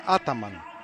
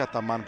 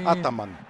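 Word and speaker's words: Ataman: Την έφαγε στο μυρό, από ό,τι κατάλαβα, ο Γκριγκόνης και μάλιστα αντικαθίσταται από τον προπονητή του, ο οποίος προφέρει το όνομά του, γι' αυτό και το λέω και τον μπερδεύω καμιά φορά, Έργιν Άταμαν. Και όχι Αταμάν που τον έχουμε μάθει τη Λίγουσα. Αυτός το προφέρει στην προπαραλίγουσα.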